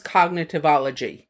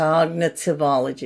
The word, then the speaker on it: cognitivology: First of all, it's pronounced cognitivology. – Yeah. Oh, that's if anybody was curious. – Yeah, cognitivology. Right. So just think of cognitive and then add ology to it.